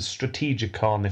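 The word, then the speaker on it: Strategikon: This book is called The Strategicon, if I'm pronouncing that correctly. – That'll do. – That'll do.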